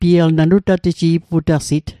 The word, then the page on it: nutateji'putasit: Mi'gmaq